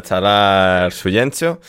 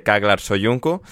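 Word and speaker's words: Çağlar Söyüncü: A ver, Chan, em, aplaudimos que eh, don Enrique Cerezo haya intentado em, pronunciarlo en turco y no haya dicho simplemente Kaglar Soyunku, o, o no.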